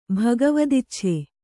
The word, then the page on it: ಭಗವದಿಚ್ಛೆ: kannada